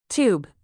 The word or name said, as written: tube